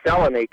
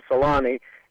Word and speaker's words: Selänne: Teemu Selänne came over here from Finland and after 1 year was convinced by the Winnipeg Jets to change his pronunciation to Selänne, and the reason was that everybody was saying it that way. Well, it doesn't make it right, and his family, I'm sure his parents, still pronounce it that way.